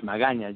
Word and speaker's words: Magaña: And nice Spanish, Magana. You said it right at the last one.